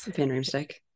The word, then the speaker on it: Van Riemsdyk: James Van Riemsdyk. Yes, I said that right. Is Van Riemsdyk.